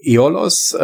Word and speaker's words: Aeolos: Ja, also Eolos äh, heißt das Spiel, wird geschrieben A-E-O-L-O-S. Ist so ein bisschen schwierig, wenn man es liest, es auszusprechen. Aber A-E spricht man eigentlich wie das Ä.